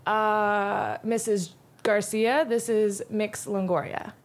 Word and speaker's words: Mx: There's mix, which is MX, and it's pronounced mix.